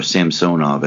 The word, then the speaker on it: Samsonov: Samsonov is only 23 years old, or Samsonov, as he pronounces it.